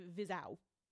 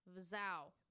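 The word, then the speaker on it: Vizhai: Nope, because this one I knew I said it wrong probably 18 times, and then I heard them say it in the video and went, wow, I was wrong. And it's spelled V-I-Z-H-A-A-H-A-Y, and it's Vizau.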